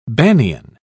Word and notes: banian: British English

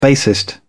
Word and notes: bassist: British English

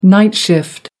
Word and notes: night shift: American English

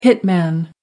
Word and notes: hitman: American English

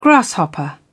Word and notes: grasshopper: British English

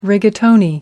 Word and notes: rigatoni: American English

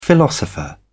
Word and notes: philosopher: British English

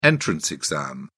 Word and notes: entrance exam: British English

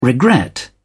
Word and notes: regret: British English

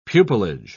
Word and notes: pupillage: British English

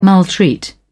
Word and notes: maltreat: British English

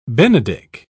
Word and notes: benedick: British English

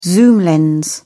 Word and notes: zoom lens: British English

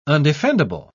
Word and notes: undefendable: British English

